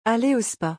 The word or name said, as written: Aller au SPA